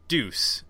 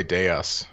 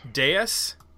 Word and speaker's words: dEUS: I'm going to say it's deuce. Is that how you say it? D, lowercase d, uppercase e-u-s. Is that right? – I was going to say deus. – Deus? Oh, okay. Deus, like Deus Machina.